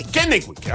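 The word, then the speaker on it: Kennewick: Kennewich. – Okay, para el área de Kennewich allá en Eastern Washington o para Portland, así que mantente en sintonía.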